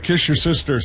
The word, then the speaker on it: Worcestershire: It's Worcestershire. – Do you know how to say it? – Kiss your sister sauce.